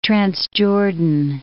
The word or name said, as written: Transjordan